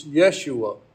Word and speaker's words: Yeshua: Now, that's the Greek pronunciation of his name. We use the word Joshua, and frequently you'll hear Jesus referred to as Joshua. But actually, in the Hebrew, it's Yeshua.